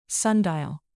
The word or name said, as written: sundial